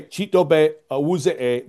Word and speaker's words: Chidobe Awuzie: So if you're going to say my name in English, Chidobe Awuzie. – Yeah. – I swear everybody in this whole press corps is like, Chidobe Awuzie. Stop it.